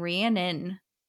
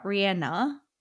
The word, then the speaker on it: Rhiannon: And I was just realizing that in the Fleetwood Mac song, they're saying Rhiannon, not Rihanna. Like, yeah, Rhiannon and Rihanna are two different.